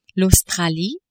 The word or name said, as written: Australie